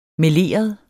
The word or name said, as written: MELEREDE